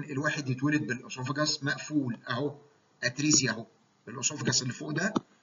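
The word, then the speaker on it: atresia: ممكن الواحد اي ليومينال ستراكشر في الجسم لو اتولد بيه مقفول تماما اسمه اتريزيا، واحيانا تنطق اتريجيا،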